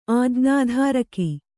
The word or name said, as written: ಆಜ್ಞಾಧಾರಕ